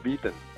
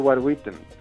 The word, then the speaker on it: Witten: Recordemos la anécdota de que Edward Witten, yo lo pronuncio en alemán, la gente creo que lo suele pronunciar en inglés, Edward Witten,